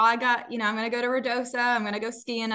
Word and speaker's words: Ruidoso: And correct my pronunciation, but Ruidoso? – You did it right. Well, it's Ruidoso.